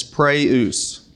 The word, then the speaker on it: praus: For gentleness, it's praeus. Can you say that one? Praeus. It's spelled P-R-A-U-S. Praeus.